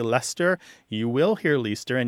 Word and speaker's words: Leicester: So a word that's spelled L-E-I-C-E-S-T-E-R did used to be pronounced something more like Leicester. Leicester. – Yeah. – But there were three syllables, and now it tends to be two, okay? Because the two syllables that sound roughly the same, the E-I-C and the E-S-T, combine to make one sound.